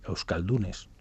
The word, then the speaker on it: euskaldunes: Con actores euskaldunes.